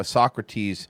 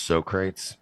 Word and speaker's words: Socrates: It's because all these empty losers think that a little stoicism or being able to do a pull-up makes you a Socrates or a tier one operator. Yeah, they're not. Turbo. – I believe it's pronounced Socrates.